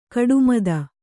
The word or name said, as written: ಕುಟ್ಟಮಿತ